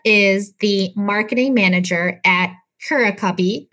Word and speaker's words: Curacubby: Am I saying that right? – You are. And to be honest, there's not like a designated way to pronounce it. I've heard Curacubby and Curacubby from people. So, okay, I'm going to stick with Curacubby because it just came naturally to me.